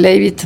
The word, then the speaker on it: Leavitt: Kenneth, a ver, lo que hoy dijo la vocera de la Casa Blanca, Caroline Leavitt, me dicen que se pronuncia,